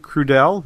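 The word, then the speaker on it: Crudele: I think he pronounces it Crudel. I don't think he pronounces the trailing E, C-R-U-D-E-L-E.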